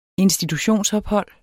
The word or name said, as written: institutionsophold